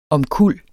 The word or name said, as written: omkuld